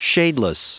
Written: shadeless

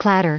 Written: clatter

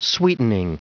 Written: sweetening

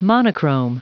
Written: monochrome

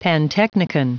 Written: pantechnicon

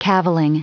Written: cavilling

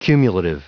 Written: cumulative

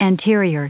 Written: anterior

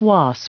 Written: wasp

